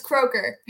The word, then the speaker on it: Croker: It's Croker. – Croker. Okay. Croker, that's how I was going to say it.